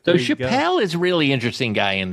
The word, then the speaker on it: Chappelle: Another Chappelle. His name's pronounced Chappelle.